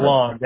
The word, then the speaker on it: LeBlanc: LeBlanc. The N C for LeBlanc. The N and C is silent.